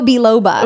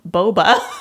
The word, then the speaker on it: biloba: Ginkgo biloba. – Ooh. – Okay. – Is that how you pronounce it? – Yeah. Ginkgo biloba. – I would always say ginkgo boba. – There's an L. – There's an L-O. Ginkgo biloba. – Biloba. Okay. There's a B-I-L-O-B-A.